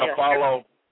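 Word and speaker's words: Afflalo: Orlando will receive Aaron Aflalo. Is that how you pronounce his name? And Al Harrington. You know, oh, Aaron Aflalo. Yeah.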